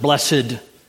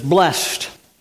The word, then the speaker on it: blessed: Sometimes we pronounce it blessed, but it's blessed.